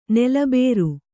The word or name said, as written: ನೆಲಬರೆ